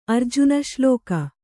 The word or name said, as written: ಅರ್ಜುನಶ್ಲೋಕ